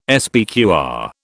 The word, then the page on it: SPQR: British English